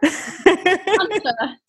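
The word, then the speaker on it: Sláinte: So correct my pronunciation if I'm wrong, but Slaunta. – Slaunta. Very good.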